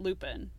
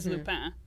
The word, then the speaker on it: Lupin: But it's not Lupin. It is Lupin. Lupin is the name of, like, the werewolf in Harry Potter, so it seems weird to say Lupin.